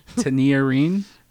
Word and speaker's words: Tinariwen: Tinariwen. How do you say it? – I fucking love it. – Tinariwen.